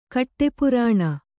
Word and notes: ಕಾಡುಪುರಾಣ: kāḍu purāṇa

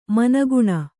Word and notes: ಮನಗುಣ: managuṇa

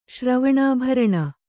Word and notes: ಶ್ರವಣಾಭರಣ: kannada

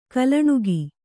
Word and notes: ಗೊಲ್ಲಣಿಗೆ: kannada

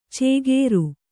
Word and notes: ಚೇಗೇರು: cēgēru

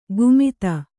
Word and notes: ಗುಮಿತ: kannada

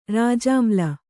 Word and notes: ರಾಜಾಮ್ಲ: rājāmla

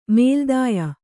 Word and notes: ಮೇಲ್ದಾಯ: mēldāya

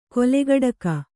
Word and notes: ಕೊಲೆಗಡಕ: kolegaḍaka

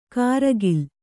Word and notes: ಕಾರಗಿಲ್: kāragil

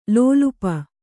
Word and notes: ಲೋಲುಪ: lōlupa

